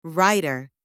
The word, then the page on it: writer: anglais